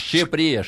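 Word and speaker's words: Shqipëria: Щепре, щепре, да. Пишется Шкиперия через Ку.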